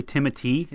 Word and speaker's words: Timothée: Supposedly it's actually Timothy. How you really say his name.